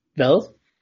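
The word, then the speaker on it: hvad: So how do you say what? – Vel. – Vel. That sounds like an L there.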